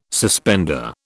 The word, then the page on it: suspender: British English